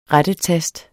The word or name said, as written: rettetast